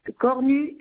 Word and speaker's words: Cornu: Cornu Yvette. – Le nom s'écrit comment? – C-O-R-N-U.